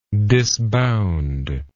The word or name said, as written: disbound